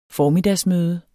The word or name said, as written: formiddagsmøde